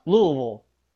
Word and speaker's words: Louisville: And they're like driving into Louisville and they're like, how do you say this? They're like, Louisville. – Really? – And then Spike Lee's like, no, it's Louisville.